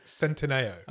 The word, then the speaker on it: Centineo: I think we've done Noah I actually found out how you pronounce his name. – Okay. – Right, it's Centineo.